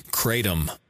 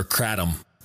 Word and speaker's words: Kratom: Kratom or Kratom. I've heard it pronounced two different ways. K-R-A-T-O-M. Kratom, Kratom, whatever you want to fucking call it.